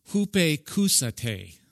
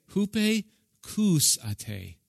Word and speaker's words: hupakousate: Hupakousate, okay? Hupakousate. If I say it right, kind of in our English, acoustics. You're hearing there acoustics, the hearing.